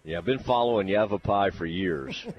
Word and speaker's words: Yavapai: They will play again coming up at 6 o'clock against Yavepi. I have never seen that before. – Yavapai. Spell it for me. – No, I think it's Yavapai. Yavapai.